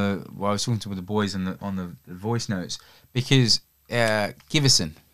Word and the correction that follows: Sigurdsson: Sigurdsson. – I see. Butchered the man's name there.